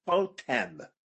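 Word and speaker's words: Chotem (חוטם): The sandals have this Chotem. Chotem? – Chotem. With a segol? – Yes.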